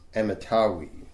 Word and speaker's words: Amittai: In the first verse here, we read how Jonah was the son of a man known as Amittai, is how you pronounce it.